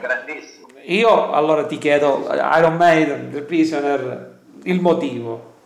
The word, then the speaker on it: Iron Maiden: Io, io, a me mi hanno preso in giro a scuola ogni volta che pronunciavo male il, il nome, Maiden, Maiden, invece Iron Maiden,